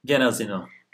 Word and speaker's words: Genazino: Genazino mu? – Genazino.